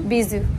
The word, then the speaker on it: bisou: Bisou.